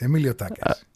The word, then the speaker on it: Emilio Takas: Emilio Takas.